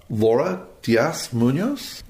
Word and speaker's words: Laura Diaz Munoz: And what I'm very, very excited about is I have the pleasure of interviewing the winemaker for Ehlers, Laura Diaz Munoz.